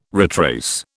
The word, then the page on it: retrace: British English